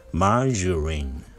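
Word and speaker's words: margarine: 発音が英語では、マージュリン。はい、マージュリン。マージュリン。はい、がじゃなくて、ジュ。はい、マージュリン。と発音します。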